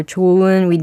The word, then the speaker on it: 좋은: But when we write 좋다 or 좋은, we don't really pronounce the heat below over here. So we just say 좋은 정도가 아니라. – Pretty much silent, right?